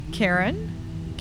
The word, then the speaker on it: Karen: Karen, K-A-R-E-N.